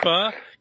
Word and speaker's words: pho: Is it F-A-A? How you spell it? – No, P-H-O. – P-H-O. – Dave, it's pho. Pho. For you, it's pho. But they pronounce it pho.